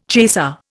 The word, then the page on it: chaser: Indian